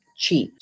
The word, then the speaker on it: qi: And in Chinese medicine, it's called qi. So it's called like qi, and it's pronounced qi.